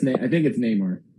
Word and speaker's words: Namor: How do you pronounce it? I'm not positive. – I think it's Namor.